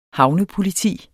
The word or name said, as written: havnepoliti